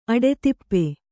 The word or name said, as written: ಅಡಿದೀಪ